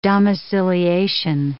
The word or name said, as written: domiciliation